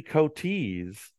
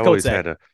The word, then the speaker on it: Coetzee: So the other two would be Corey Sanders, who defeated Vladimir Klitschko, and Jerry Coetzee. I think that's how you pronounce it. – I think it's Coetzee.